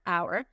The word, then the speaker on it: hour: La palabra hora en inglés, yo sé que es difícil de pronunciar, la palabra hora en inglés se dice hour, hour, hour, a, u, a, agua, agua, agua. Piensen en decir agua, le quitan la G, agua, y le meten la R, hour, hour, hour.